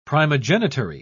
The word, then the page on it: primogenitary: British English